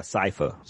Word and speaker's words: Sypha: I can see that because that first episode with them sawing, I pronounce her name is Cypher, remember? Because that's probably why we probably wouldn't even thought that it was, and that it's spelt like Cypher too. That's crazy, like Sypha.